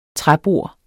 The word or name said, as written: træbord